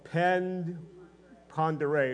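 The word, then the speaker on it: Pend Oreille: Sandpoint has this ginormous lake called Pend Pondere. It's a French word, but you say it different than it's spelled, kind of like Belle Fountains, Belle Fontaine, that kind of thing.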